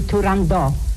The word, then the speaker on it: Turandot: In addition to Turandot, which is pronounced the way I pronounce it, and also it was pronounced by Puccini and Toscanini, so it's really Turandot, not Turandot.